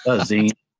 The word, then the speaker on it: zines: am I saying that right? Zines. – Zines.